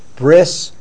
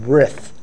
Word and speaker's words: Brit: So they call it Brith Kodesh. So you might hear it called Brith Kodesh, or a Bris is another way. There's actually three pronunciations of it. Bris, Brit, and Brith.